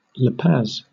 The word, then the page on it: La Paz: /lə ˈpæz/; Southern England